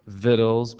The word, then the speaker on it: victuals: I know that word doesn't look like victuals, but look it up in the dictionary. That's how it's pronounced. So the old timers used to say it's time to fix victuals. But that's how you pronounce that word.